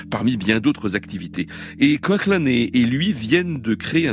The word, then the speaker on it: Coughlan: Et alors, Coughlan a publié récemment un album solo, le premier depuis 10 ans, qui s'appelle Song of Coughlan, ça s'écrit C-O-A-K-L-A-N, ce qui est une clé, voilà, pour prononcer son nom, qui s'écrit Coughlan, si vous voulez.